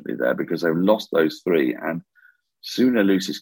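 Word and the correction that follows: Luus: captaincy, or Lise, as it turns out, that it should actually be pronounced. Two U's make an E in Afrikaans. So Suna Lise, as captain, I'm afraid just hasn't cut the mustard.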